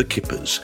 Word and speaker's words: Hkippers: He's also the lead vocalist and guitarist and sellotape player in the group The Kippers, spelt H-K-I-P-P-E-R-S. Yes, you see, the H is silent.